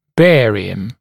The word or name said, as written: barium